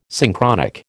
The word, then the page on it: Synchronic: American English